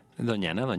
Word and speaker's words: Doñana: Entonces, esto se llama justo Doñana. – Sí. – ¿Lo dije bien? – Doñana.